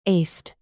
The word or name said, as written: aced